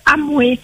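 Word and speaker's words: Hamwe: Et le bateau, il s'appelle Amoué. Alors ça s'écrit H A. – Oui. – M W E.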